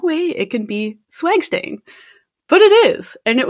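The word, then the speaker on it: SWGSTAIN: And in the States, they have the Scientific Working Group on Blood Stain Pattern Analysis, which I kind of laugh at because it's condensed to swag stain. I mean, like, it's without the A, but I pronounce it as swag stain.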